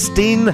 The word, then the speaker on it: S10: it's actually spelled like... simply S and then number 10. But her name is Steen, and the song is De Diepte.